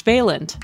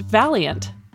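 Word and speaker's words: Vaillant: Finally, Angela mispronounces the name of the Harvard Medical School psychiatrist who led longitudinal studies that followed subjects for decades. She refers to him as George Valiant, but his last name is actually pronounced Valiant, a suitable aptronym given his bold work investigating how individuals evolve over the course of their lives.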